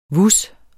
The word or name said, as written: VUS